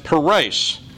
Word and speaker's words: peres: The first occurrence is at the beginning of the verse, and it's pronounced peres, half mina. Its second occurrence is a passive verb written in the PL or intensive stem where it's pronounced pirasat and translated divided. And then the third occurrence is the last word in the sentence, paras, which means Persians.